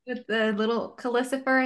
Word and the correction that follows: Calcifer: I think I'm mispronouncing it, but it's like Calicifer, something like that. – Calcifer, Calcifer.